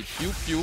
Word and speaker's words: piou-piou: Mais c'est parce que vous le prononcez comme un laser, là. C'est pas un laser, là, c'est piou-piou.